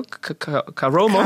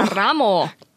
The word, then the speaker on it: Karamo: Dann gibt es noch den Afroamerikaner Caroma. Heißt er so? Caramo. – Caramo. – Karamo.